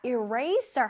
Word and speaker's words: eraser: 자, eraser 할 때 eraser라고 우리 친구들이 할 텐데 eraser가 절대 아니라 eraser. 자, eraser하고 sir를 아주 강렬하게 발음을 해줍니다. eraser.